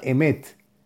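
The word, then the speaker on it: Emet (אמת): Hay un nombre sagrado para meditar que está compuesto por las letras Aleph, Mem, Taf y se pronuncia Emet y quiere decir verdad.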